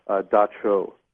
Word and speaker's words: dab tsog: It's spelled D-A-B-T-S-O-G, and I understand that it's pronounced Dacho.